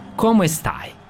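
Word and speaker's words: Come stai: Komo estaj? – Bene, grazie. – Jesam dobro izgovorio ovo? – Dobro si izgovorio, jes.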